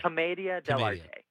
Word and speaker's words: commedia dell'arte: Tell me how to say it again. Commedia. – Commedia dell'arte. – Dell'arte. Okay. That makes sense now, because I was reading that you guys have all studied this.